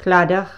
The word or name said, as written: CLADDACH